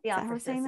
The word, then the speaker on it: Theophrastus: Theophrastus is how we're saying it.